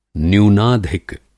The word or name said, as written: न्यूनाधिक